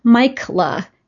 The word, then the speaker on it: Mikela: And so now a great way for people to remember how to spell it is Mikela, M-I-K-E-L-A.